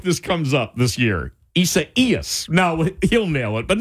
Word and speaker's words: Isaias: Here's how to say that name. Isaias. Isaias. The accent goes on the second E, which is the I, and you say Hurricane Isaias.